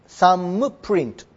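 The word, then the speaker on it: thumbprint: サム THUMP、サム親指のインプレッション、あるいはサムプリント、サムプリントとも言う。これも THUMB にそのプリントをつけていただけます。 B は発音しませんから、サムプリント、これは母音でございます。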